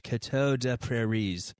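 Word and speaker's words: Coteau des Prairies: Like if you said it in French, Jackie would probably assault my pronunciation there. But like say it like you're French. Coteau de Prairies.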